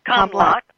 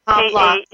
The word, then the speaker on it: Kamlak: I know that you have your site is Comlac. Am I saying that right? – Comlac. – Comlac. – K-A-M-L-A-K.